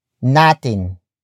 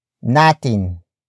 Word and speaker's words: natin: It is followed by natin, natin, natin.